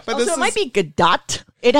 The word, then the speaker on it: Gadot: So yeah, so we've got people like Shirley MacLaine to Gal Gadot to... – Gadot.